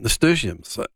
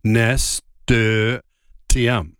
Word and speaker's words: nasturtium: you've probably seen nasturtiums. Now, I didn't even quite know how to say it. Just have a crack at this. It's NASTURT, nasturtium. – I would say nasturtium as well.